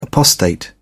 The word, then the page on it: apostate: British English